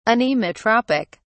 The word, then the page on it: anemotropic: British English